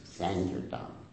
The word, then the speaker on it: sankirtan: Most devotees pronounce it as sankirtan. That's the wrong pronunciation. Sankirtan.